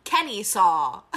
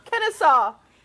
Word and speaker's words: Kennesaw: Kenny saw. – How is it pronounced? – Kenessa? No? – Kennesaw.